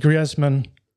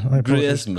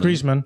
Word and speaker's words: Griezmann: and actually Griezmann I pronounced his name in a very weird way again. – Griezmann. Griezmann.